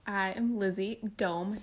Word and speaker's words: Dohm: That's the correct way to pronounce her name. In case you were like me and you pronounced it wrong for the first 3 years you knew her, Elizabeth Dohm is our guest today.